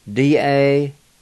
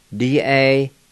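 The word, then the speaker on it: dawoli: And it's spelled K-U-W-A. K-U-W-A. Number three is murderer. D-I-D-A-H-I-H-I. D-I-D-A-H-I-H-I. Number four is mushroom. And it's spelled D-A-W-O-L-I. D-A-W-O-L-I. Number five is nail, and it's spelled Y-V-G-I. Y-V-G-I.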